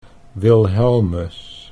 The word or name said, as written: Wilhelmus